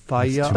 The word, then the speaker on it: Fieri: They say F-A-H-Y-U-H, and then a pause, R-E-E. Ferrari? – Wait, F-A-H is fa. – Fa. – Doe a deer, a female deer. – Fayari.